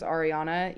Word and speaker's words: Ariana: And like, when you go to pronounce like my first name also, my first name is Ariana, you're typically supposed to roll the R as well.